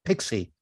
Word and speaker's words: PowerShot PX: And let us move on to another rather silly one, the Canon PowerShot PX, pronounced Pixie,